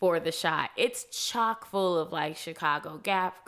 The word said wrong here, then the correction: gaffers: or I always say this name wrong, G-A-F-F-E-R, gaffers.